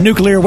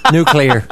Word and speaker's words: nuclear: In America, we say nuclear.